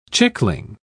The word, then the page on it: chickling: British English